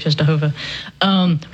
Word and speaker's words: Częstochowa: but to Our Lady of Częstochowa, which is my bad pronunciation of Polish. – Close enough.